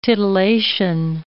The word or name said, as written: titillation